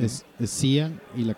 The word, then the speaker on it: Sia: con un crush con esta mujer, con Sia, que ya me dijeron que se pronuncia Sia. – Yo pensé que era más mamón ir era Shaya o Saya.